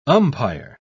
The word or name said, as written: umpire